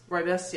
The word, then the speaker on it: rooibos: They're rooibos. – Rooibos, yeah. – Rooibos is how you say it.